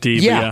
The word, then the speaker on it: Die: We had somebody from the country message us and say how much they loved it, but they did say it rhymes with tree. So every time Jason calls it Die Bundesliga, I just mention it's D because that's what they ask. D-I-E.